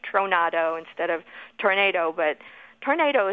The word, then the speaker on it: tronado: Our word tornado actually comes from the Spanish word tronado, which means storm, and it's spelled T-R-O-N-A-D-O, tronado.